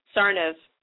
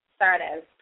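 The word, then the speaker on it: Tsarnaev: Oh, his last name? – Yes. – Sarnov. – Sarnov. Perfect.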